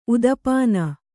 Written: ಉದಪಾನ

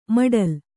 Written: ಮೊಟಲೆ

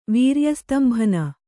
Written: ವೀರ್ಯಸ್ತಂಭನ

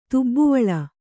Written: ತುಬ್ಬುವಳ

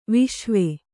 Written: ವಿಷುವ

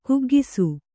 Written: ಕುಗ್ಗಿಸು

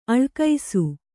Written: ಅಳಕಿಸು

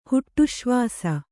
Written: ಹುಟ್ಟುಶ್ವಾಸ

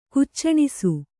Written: ಕುಚ್ಚಣಿಸು

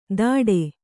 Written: ಧಡೆ

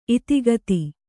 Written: ಇತಿಗತಿ

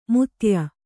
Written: ಮುತ್ತಯ್ಯ

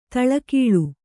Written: ಧಾಳಿಗೊಳ್

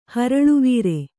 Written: ಹರಳುವೀರೆ

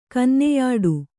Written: ಕನ್ನೆಯಾಡು

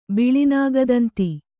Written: ಬಿಳಿನಾಗದಂತಿ

